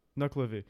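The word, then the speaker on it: Nuckelavee: Nuklavi, which is Nuklavi? Nuklevi.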